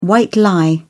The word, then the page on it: white lie: British English